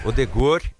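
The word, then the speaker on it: Ødegaard: O Odegaard, como é que fala Odegaard mesmo? Odegaard, é assim? – Odegaard e o Haaland, né?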